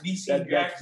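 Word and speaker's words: DeSean: Yeah, Isn't it S-H-A-N? – I don't know. – It's D-E-S-E-A-N. – Oh, no. That's Deshaun Jackson. D-Scene Jackson.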